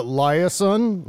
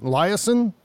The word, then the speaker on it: Liasson: I think, and I'm going to botch her name, the pronunciation of her name, Mara Liasson. Liasson? Mara Liasson.